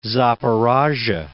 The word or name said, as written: Zaporozh'ye